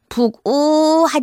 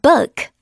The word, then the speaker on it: book: 친구들 책 하면 북 이렇게 동동동 둥둥둥 치는 북 막 이렇게 발음하는 친구들이 있는데 북우 하지 마시고요. 오히려 약간 으, 으 사운드에 가깝게 a book, book 이렇게 발음해 주세요.